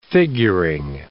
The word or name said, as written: figuring